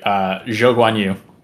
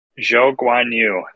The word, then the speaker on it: Zhou Guanyu: I'll do it for you. Zhou Guanyu. – Zhou Guanyu. There we go.